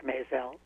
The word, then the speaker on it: Mizell: It's Mizell, Eric Mizell. Like Mizell. – May and Gazelle.